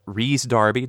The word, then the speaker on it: Rhys Darby: In the original series, Coran, who is now being played by Rhys Darby. Did I pronounce it right this time? – Perfect.